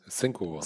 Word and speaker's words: Cinco: Aber gehe ich richtig in Arme, dass Cinco Capital, oder wie spricht man es aus? – Cinco, Spanisch für 5.